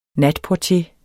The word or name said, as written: natportier